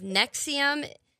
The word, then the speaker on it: NXIVM: So we're going to be going over the story of Nexium. I couldn't figure out how to pronounce it. It is spelled N-X-I-V-M.